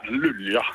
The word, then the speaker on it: Luleå: En dit valt nu ook op in de gemeente van Luleå. Zeg ik dat goed? – Ja, Lulia. Lulia. Zeggen ze ook wel.